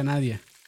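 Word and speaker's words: Nadiya: es que se escribe Nadilla, pero se pronuncia Nadia.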